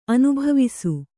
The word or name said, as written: ಅನುಭಾವಿಸು